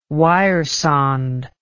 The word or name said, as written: wiresonde